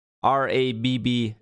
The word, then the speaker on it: Rabbit: So for our listeners that don't know how it works, Rabbit, it's rabb.it. That's the website, but they pronounce it Rabbit because they reached out to me and I talked with them a little bit.